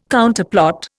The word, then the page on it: Counterplot: Indian